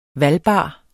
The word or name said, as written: valgbar